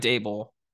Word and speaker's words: Daboll: I just talked about the Vikings, but Brian Daboll rhymes with table.